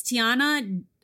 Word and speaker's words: Theana: It's Tiana underscore Shaw. S-H-A-W. And my first name is spelled T-H-E-A-N-A.